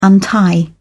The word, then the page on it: untie: British English